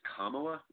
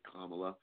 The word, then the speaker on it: Kamala: First of all, I want to start by saying it appears that she's having her name pronounced as Kamala with the emphasis on the first syllable. And I'm going to go with that. I'm going to go with Kamala.